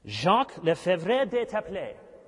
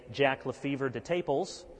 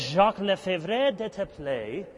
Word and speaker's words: Jacques Lefebvre d'Etaples: Jacques Lefebvre d'Etaples. I think that's the French pronunciation. We might say Jack Lefebvre de Taples. But I think it's Jacques Lefebvre d'Etaples.